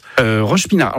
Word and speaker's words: Roche-Pinard: Vous changez de quartier, à Roche-Pinard. Alors, c'est ça, hein, c'est bien comme ça qu'on prononce. – Roche-Pinard.